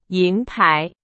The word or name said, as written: 银牌